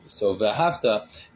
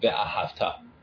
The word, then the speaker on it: v'ahavta: So v'ahavta needs to be pronounced as a command. As a vav hipoch, it needs to be on the last syllable, not ve'ahavta. Ve'ahavta would be, and you did love.